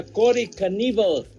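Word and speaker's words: Knebel: no, así se pronuncia Cory Caníbal.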